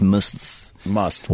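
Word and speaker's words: musth: They blame the elephant's aggressive behavior on the fact that he was in a heightened state of sexual tension known as must. – What? – Yeah. Must. You don't pronounce the H? – I don't think so. Must. Like must. Must.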